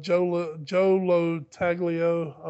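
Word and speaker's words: Joe Lo Truglio: But you know, Andy Samberg, Andre Brower, Joe Lo Taglio, I believe is how you say his name.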